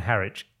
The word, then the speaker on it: Harwich: In the late 90s, my best mate Jamie and I decided for reasons unknown to spend a week in Harwich. Is that how you pronounce it? – Weekend in Harwich.